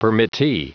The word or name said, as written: permittee